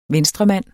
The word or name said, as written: venstremand